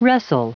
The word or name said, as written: wrestle